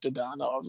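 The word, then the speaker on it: Dadonov: I think it's pronounced Dadanov, though.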